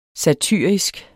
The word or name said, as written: satyrisk